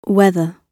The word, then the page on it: whether: British English